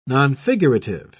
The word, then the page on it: nonfigurative: British English